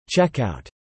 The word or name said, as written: checkout